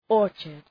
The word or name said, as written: orchard